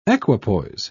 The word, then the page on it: equipoise: British English